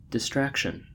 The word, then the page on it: distraction: American English